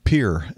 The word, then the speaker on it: Pierre: Like Pier instead of Pierre in South Dakota. – Oh, I didn't know that one either. – Sort of like that. – Interesting. – Yeah, it's Pier.